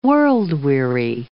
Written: world-weary

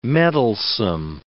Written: meddlesome

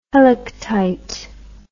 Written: helictite

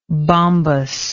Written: bombous

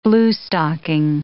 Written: bluestocking

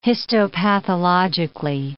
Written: histopathologically

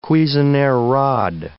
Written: Cuisenaire rod

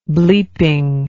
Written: bleeping

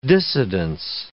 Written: dissidence

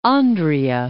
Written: Andria

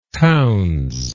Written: townes

